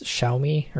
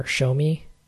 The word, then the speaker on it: Xiaomi: I think it's Xiaomi or Xiaomi. XIAOMI is the company.